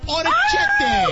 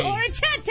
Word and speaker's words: orecchiette: Orecchiette. – Orecchiette.